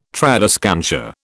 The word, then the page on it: tradescantia: British English